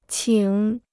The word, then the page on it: 请: qǐng